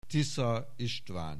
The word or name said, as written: Tisza István